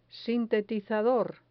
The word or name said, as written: sintetizador